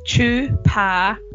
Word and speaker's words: Chupacabra: For those who don't know how to pronounce it, it's Chupacabra. So if you want to break it down, it's Chupacabra.